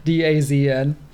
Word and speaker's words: DAZN: I've just learned that this is how you say DAZN. – Oh. – DAZN. You have to invent a few vowels in there.